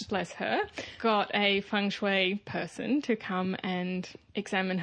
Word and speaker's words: feng shui: Uh, but before we get anywhere, you were mentioning just before we started something about feng shui. Feng, feng, feng shui.